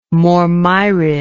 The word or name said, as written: mormyrid